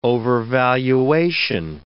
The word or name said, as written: overvaluation